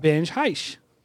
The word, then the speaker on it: Benj Haisch: We got Benj Haisch.